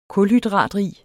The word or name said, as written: kulhydratrig